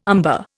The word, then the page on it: umber: Indian